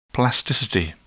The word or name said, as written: plasticity